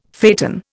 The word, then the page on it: phaeton: Indian